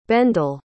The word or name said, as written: bendel